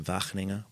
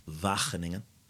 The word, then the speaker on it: Wageningen: She lives in Wageningen, which is wherever it is. – You said that beautifully, by the way. Well, I do have good pronunciation, I've been told. Wageningen.